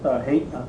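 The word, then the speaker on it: Haight: Kuschel's book, how do we pronounce that? Hate? Height? – Hate.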